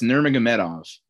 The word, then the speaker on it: Nurmagomedov: It's Nurmagomedov.